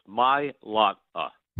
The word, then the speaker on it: Mailata: Yes, three syllables. Mailata.